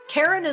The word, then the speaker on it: Karen: Karen is spelled the normal way, no goofy Y's or Q's, K-A-R-E-N, and Cataline is spelled K-A-T-A-L-I-N-E.